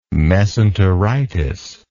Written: mesenteritis

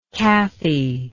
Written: Cathy

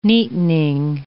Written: neatening

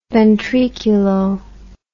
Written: ventriculo

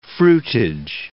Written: fruitage

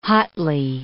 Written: hotly

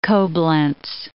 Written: Coblenz

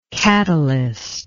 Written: katalyst

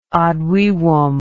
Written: ad vivum

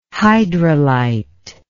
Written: hydrolyte

